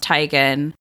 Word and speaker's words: Teigen: Really, her name is pronounced Teigen. – Is it really? – Yeah, her name is Chrissy Teigen. – Why doesn't she correct people? – Because everyone says Teigen, and so she said it's like too late.